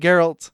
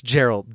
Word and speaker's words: Geralt: In the show, they say Geralt. In the audiobook version of the books I'm listening to, they say Geralt. Okay. I've always pronounced it Geralt. – Okay. – But I do know people that are like, it's Gerald, diehards.